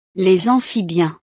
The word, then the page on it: les amphibiens: frances